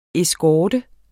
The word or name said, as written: eskorte